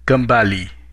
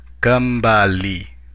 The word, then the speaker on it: kembali: In Indonesian, your welcome is kembali. Kembali. Let's break it down by syllable. Kembali. Now let's hear it once again.